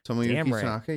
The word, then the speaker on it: Tomoyuki Tanaka: So when I read that the producer for this movie, Tomoyuki Tanaka, did I say that right? – Tomoyuki Tanaka, yep.